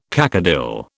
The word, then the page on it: cacodyl: British English